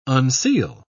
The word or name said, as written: unseal